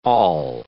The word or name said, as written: all